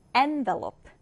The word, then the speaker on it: envelope: 자, 이 단어 발음을 주의해 주세요. 일단은 첫 음절에 일 강세를 두고 envelope, envelope입니다.